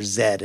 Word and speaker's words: Z: That was about an explorer who disappeared in the Amazon looking for a fabled city he called the City of Z, or Zed, as he pronounced it, because he was British.